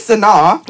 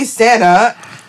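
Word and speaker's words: Sanaa: Like, okay, like, my name is Sana. – Yeah. – But then they would say Santa.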